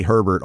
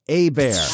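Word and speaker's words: Hebert: Of course you hear Kinspiracy call me Herbert all the time, but my last name is not spelled Herbert. It's H-E-B-E-R-T. It's Louisiana Cajun French. A-Bear.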